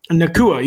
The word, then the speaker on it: Nacua: Puka Nacua. I'm sorry if I'm mispronouncing his name. – Nacua.